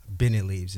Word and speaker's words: Beneleaves: B-E-N-E-L-E-A-V-E-S. It's for beneficial leaves. So Benny Leaves is the name of the company.